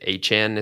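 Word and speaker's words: Achane: Achan is how you say his name. Achan. Yeah, that's what he said.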